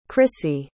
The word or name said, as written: crissie